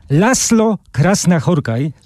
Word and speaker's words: László Krasznahorkai: Węgrzy piszą SZ, tak jak u nas szy, a my czytamy S. László Krasznahorkai, to jest literacki noblista tegoroczny, przypominam, bo już podaliśmy tę informację, już omówiliśmy wstępnie.